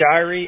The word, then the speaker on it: Shirey: It's Shirey, S-H-I-R-E-Y. – S-H-I-R-E-Y?